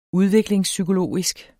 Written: udviklingspsykologisk

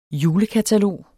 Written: julekatalog

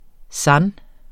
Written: sans

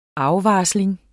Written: afvarsling

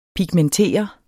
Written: PIGMENTER

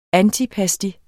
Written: ANTIPASTI

